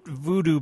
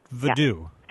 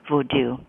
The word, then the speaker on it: vodou: Not voodoo, but vodou. – Yeah, well, that's the way you pronounce it in Haiti. And also, increasingly, scholars of religion here in the U.S. and around the world pronounce it that way, vodou. And that's how the Library of Congress says it too.